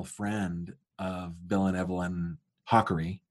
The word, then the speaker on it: Hakari: So it is not Hakari. – Right. – It is Hakari.